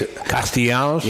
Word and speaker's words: Nick Castellanos: Nick Castellanos? – Yeah, and I tell you, that guy.